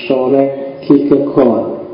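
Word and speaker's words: Søren Kierkegaard: Denmark Namanya adalah Soren Kierkegaard Kalau pakai lidahnya orang sana biasanya membacanya agak bulat di belakang jadi sore kikekon jadi rodok masuk ke kerongkongan suaranya jadi jadi O bukan A tapi ya tidak apa-apa mungkin agak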